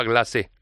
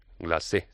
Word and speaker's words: Glacé: Después de llevar varios meses sin editar nueva música, Nati Peluso nos sorprende con este Copa Glacé, Glacé, se dice así, Glacé, que lleva un rollito muy en la onda de esas canciones navideñas, ojo, canciones navideñas, no villancicos, no hay que confundirse, ¿eh?